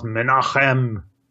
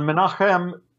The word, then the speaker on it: Menachem: I think this is pronounced Menachem. – Menachem? – Really, but I'll try to pronounce it Menachem.